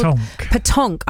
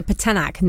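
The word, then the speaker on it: pétanque: The one that got me, and I can never pronounce it correctly, so I want you to say it with the P. What's it called? – Petonk. – I kept calling it the Petanak, and my husband Sonny was like, no, that's totally wrong.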